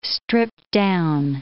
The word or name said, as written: stripped-down